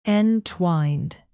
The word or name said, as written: entwined